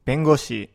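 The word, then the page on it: bengoshi: Japanese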